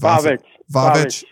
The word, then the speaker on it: Vavic: The USC's men's and women's water polo coach, Jovan Vasic, is it Vasic or Vak? I don't know how to pronounce it. – Vavic.